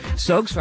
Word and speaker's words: Suggs: Suggs rhymes with hugs, which are what you might give someone that you haven't seen for a very long time.